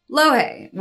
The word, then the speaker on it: Louhi: The character who directly inspired Igwilv was Lohei, which is pronounced which is spelled L-O-U-H-I.